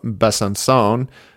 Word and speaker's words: Besançon: And then on Sunday, we have the home race of Carolyn Mani at World Cup Besançon in France. I asked her how to pronounce it. That little C with the little wavy, with the C with the tail, I guess makes an S sound.